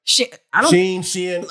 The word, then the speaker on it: Shein: Shein.